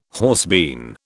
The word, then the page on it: horsebean: British English